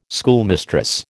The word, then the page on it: schoolmistress: American English